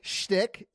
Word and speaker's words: shtick: How do you say that? Stick. – Shtick. It's shtick.